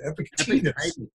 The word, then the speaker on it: Epictetus: Epictetus.